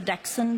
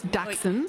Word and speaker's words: Dachshund: So you'll have the Dachshund breeds, all the varieties of Dachshund. – That's how you say it. I've been saying it wrong my whole life. Thank you very much. Dachshund.